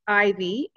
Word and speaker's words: Aevi: It's spelled AEVI, but pronounced Ivy.